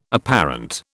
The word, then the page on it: apparent: British English